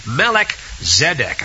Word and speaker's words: Melchizedek: but I like Melchizedek.